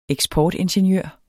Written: eksportingeniør

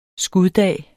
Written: skuddag

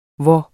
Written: hvor

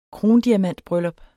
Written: krondiamantbryllup

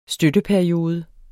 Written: støtteperiode

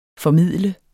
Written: formidle

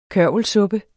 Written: kørvelsuppe

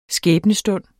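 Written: skæbnestund

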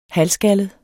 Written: halvskaldet

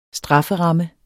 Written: strafferamme

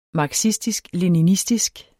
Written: marxistisk-leninistisk

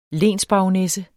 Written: LENSBARONESSENS

